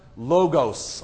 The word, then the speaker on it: Logos: He is literally the embodiment or the incarnate logos or logos, however you want to pronounce it. How do you pronounce that? Logos, thank you.